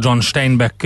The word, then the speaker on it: John Steinbeck: E, aztán két komoly író, John Steinbeck, e, ugye jó magyarosan kiejtve Nobel-díjas amerikai író, 1902-ben, és egy pár évvel rá, 1913-ban Irvin Shaw, amerikai író szintén.